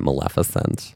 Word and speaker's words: Maleficent: The world cannot forget Maleficent.